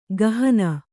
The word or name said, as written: ಕುಹನ